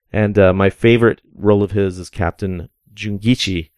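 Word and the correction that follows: Jinguji: Nope, I said that wrong. Captain Jinguchi in 1963's Atragon.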